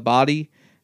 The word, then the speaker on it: Bodie: The natives of the area call it Bodie. It is phonetically, it is Bodie, and it is spelled like Bodie, like the character. I'm Johnny Utah. That's Bodie.